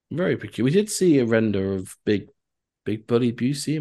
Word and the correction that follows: Busick: am I pronouncing that? – Busick. – Busick this morning, Dan, apparently.